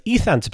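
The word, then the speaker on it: U Thant: But if you're being pernickety and look up the Wikipedia article about how to pronounce the former Secretary General's name, Ethant. Don't expect any research beyond this point. This is my research time finished. But Ethant, apparently, is how you pronounce the bloke's name.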